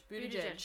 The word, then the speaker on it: Buttigieg: Pete, is it Buttigieg or Buttigieg? – Buttigieg. – See, I have a dear friend whose surname is spelled the same way, and she pronounces it Buttigieg, so I'm like, what?